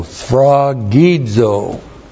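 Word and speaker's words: sphragizo (σφραγίζω): S P-H-R-A-G-I-Z, long O, sphragizo.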